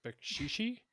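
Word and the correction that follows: Beksiński: Bekshinsky. – Bekshinsky.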